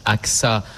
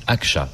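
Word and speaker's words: Ağca: Alors, ça se prononce AXA en français, mais en turc, Akça, puisqu'il était turc.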